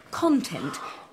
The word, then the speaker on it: content: When you said content, you put the emphasis on the first syllable. Content, which is very American, I think.